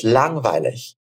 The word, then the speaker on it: langweilig: Esse G de lang, langweilig, ele não é pronunciado. A gente não fala lang, langweilig, né? A gente fala lang. É como se fosse uma nasalização ali, assim, né?